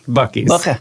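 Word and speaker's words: Buc-ee's: There's this new place called, what is it called? Busey's? Bucky's. – Bucky's. – They spell it so strange, right? And Bucky's, you would think there's a KY in it, but no.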